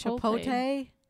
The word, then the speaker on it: Chipotle: I don't even know how to say it wrong. It's Chipotle. – Chipotle. They put the L and the T in the wrong spot.